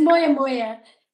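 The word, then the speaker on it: moyamoya: And then I know you were diagnosed with, now hopefully I'm going to say this correctly, Maya. Is that correct how I say it? – Or Moya. – Okay. – It's Moya. Yeah.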